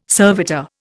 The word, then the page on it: servitor: Indian